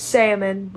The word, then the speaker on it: salmon: Well, technically speaking, the L is silent. – Why do they put it there though? – And it's salmon, but I say salmon.